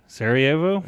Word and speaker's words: Sarajevo: The event had sparked the beginning of the war, throwing the whole city of Sarajevo into chaos. All right? – What a mean dude. – I'm proud of you for saying that city. – Sarajevo? – Yeah.